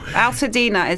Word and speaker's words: Altadena: Altadena is near Pasadena. So it rhymes.